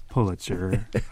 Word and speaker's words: Pulitzer: And to our listener out there who always sends me emails that we say Pulitzer, it is Pulitzer, and I pronounced it correctly.